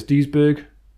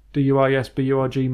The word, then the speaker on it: Duisburg: Duisburg, Duisburg, man-eater.